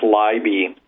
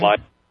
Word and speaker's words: Leiby: It's one of those names with the spelling, but it's just pronounced Leiby. – Leiby, okay. – Leiby, yeah.